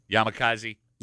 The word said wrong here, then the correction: Yamazaki: Yeah. – Yamazaki. I always say it wrong.